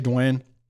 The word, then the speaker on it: Duane: They always do D-E-W or D-W-A-Y-N-E. And I've realized that if I have them put Dwayne, D-U-A-N-E, if you haven't heard the name and you look at it, you don't know how to say it. And it's, and I don't like doing that to people, you know, Dwan, cause it's like Dwan, you know, I mean, it's like, it's hard.